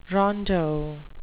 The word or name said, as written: rondeau